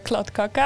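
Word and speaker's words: kladdkaka: For me, it's definitely the klot kaka. I don't know if I pronounce it correctly. – Yeah. I know you said it perfectly.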